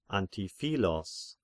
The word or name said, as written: Antiphilos